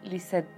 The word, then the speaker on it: Lisette: En Facebook me encuentras por mi nombre, Lisette Morales de Mané, y en Instagram como arroba lisette.psicóloga.